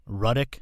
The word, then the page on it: Jeanette Ruddick: r UH - d ih k